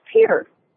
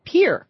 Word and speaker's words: Pierre: So I would like to go listening on Sirius in Pierre, South Dakota. Is that the correct pronunciation? – It's actually pronounced Pierre. – Pierre.